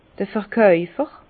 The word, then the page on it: d'r Verkäufer: Alsatian; Bas-Rhin; Herrlisheim